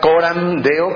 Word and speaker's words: Coram Deo: Reciban un saludo de la Iglesia Reformada Coram Deo, con M al final en la palabra Coram, acento en la O, Deo, y es una expresión en latín que significa delante del rostro de Dios, y esto tiene, digamos que dos implicaciones.